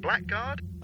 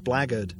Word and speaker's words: blackguard: Blackguard?" – "I think it's pronounced blaggard," Gerard said, moving the skimmer close to the material clogging the inside of the Axon.